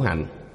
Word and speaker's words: hạnh: Vô lượng kiếp trung tu khổ hạnh. Chữ hành này vẽ một vòng tròn, dùng làm động từ, đọc là hạnh, khổ hạnh.